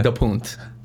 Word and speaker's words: DaPonte: In Portuguese? Yeah. DaPonte. – You should say that. – Frankie DaPonte. – That sounds amazing. – Yeah. I guess I could start pronouncing it that way. But like, you're always changing your pronunciation. – But for today, it's DaPonte.